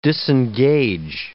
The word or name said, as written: disengage